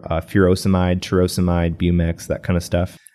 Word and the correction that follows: torsemide: Isn't it torsemide? – Torsemide, I guess, would be the proper way to pronounce that.